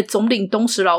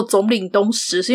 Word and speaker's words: 惣領冬実: 它是由那位总领东石老师所绘制的。呃，至于为什么我会念作总领东石，是因为。那个字啊，齁，虽然我们可以查到叫做念总，但其实它算是日文汉字。